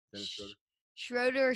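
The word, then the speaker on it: Schröder: Am I saying that right? – Yep, Dennis Schroeder.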